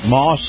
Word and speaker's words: Maus: Moss. That's spelled M-A-U-S, Moss Family Automotive.